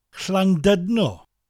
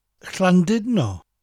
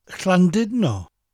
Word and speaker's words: Llandudno: Llandudno has a U in the middle of the word, so English speakers often pronounce it as dud, Llandudno. That letter U in Welsh has an I sound, so it's Llandudno.